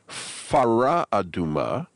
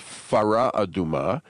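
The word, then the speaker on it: fara aduma (פרה אדומה): For example, in Parshat Parah, where the Torah speaks of the command to take a fara aduma with an F sound, fara aduma, because it's v'yikhu'elecha fara aduma, and it follows a chaf with a kamatz, which is like a silent A, even though it doesn't have a silent A, and it loses it.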